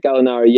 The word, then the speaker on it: Gallinari: Is that how you say it? – Gallinari, yeah, he is, and that's one of the big question marks on him.